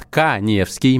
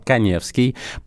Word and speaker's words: Каневский: Заходим в Инстаграм, находим меня, Эдуард Каневский, моя фамилия пишется через А, Эдуард Каневский.